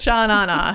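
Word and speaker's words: Sha Na Na: Sha-na-na? Is that how you say it? Just Sha-na-na. Yeah. Sha-na-na. There's no extra na's.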